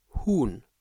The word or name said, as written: Huhn